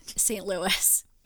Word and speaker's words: St. Louis: St. Louis.